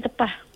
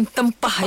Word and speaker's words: tepah: Masuk tanya dia betul tak betul ke besok panggil tepah. – Tepah. – Ejaan kan ayu? Ejaan dia T-E-P-A-S ataupun T-E-P-A-H. Dia boleh laku dua-dua tu. – Bukan tempah ya, tepah. – Bukan tempah. Tempah itu order.